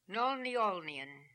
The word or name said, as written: Nolniolnian